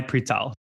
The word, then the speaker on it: přítel: Is it přítel? – Přítel, kamarád.